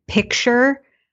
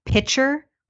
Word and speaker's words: picture: I talk about in my book a woman whose boyfriend wanted to break up with her because she pronounced the word picture, like a picture on the wall, as pitcher, like a pitcher of water.